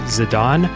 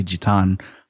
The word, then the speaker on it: Zidane: So in Final Fantasy IX, you control a character named Zidane. Just going to get that out of the way real quick. That's how I pronounce his name, like the headbutting soccer player.